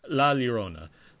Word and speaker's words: La Llorona: And this week we're talking The Curse of La Llorona. Um, I don't know how to say this uh, what? Uh, yeah, uh, it's uh, Llorona. – La Llorona.